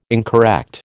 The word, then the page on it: incorrect: American English